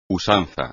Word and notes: usanza: espagnol

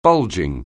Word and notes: bulging: anglais

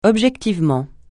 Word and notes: objectivement: francais

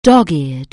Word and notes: dog-eared: anglais

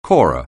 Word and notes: corer: anglais

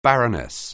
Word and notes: baroness: anglais

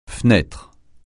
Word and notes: fendu: francais

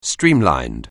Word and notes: streamlined: anglais

